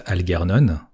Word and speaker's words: Algernon: enfin, des fleurs pour Algernon, parce qu'on s'est mis d'accord sur le fait qu'on allait prononcer ça Algernon.